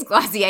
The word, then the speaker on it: Glossier: I think it's Glossier. It's spelled Glossier, but like you don't say Glossier because it's French. – G-L-O-S-S-I-E-R.